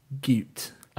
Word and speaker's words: Goot: Also, my name is pronounced like Newt with a G. Goot.